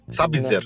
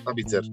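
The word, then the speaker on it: Sabitzer: Σάμπιτζερ. Ναι. – Σάμπιτζερ.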